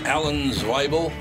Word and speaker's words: Zweibel: Alan Zweibel, is that what you think I should go with? – Sounds right. – All right, I think that's I think that is how he pronounces his name.